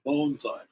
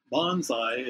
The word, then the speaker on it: bonsai: the true pronunciation is bonsai, like bone, meaning a plant in pot. The other, bonsai, is a little bit more aggressive.